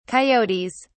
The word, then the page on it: coyotes: British English